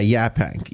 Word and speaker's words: Yaphank: Is it, I believe I pronounce it Yaphank. Is that correct? – Yeah, Yaphank. Yes.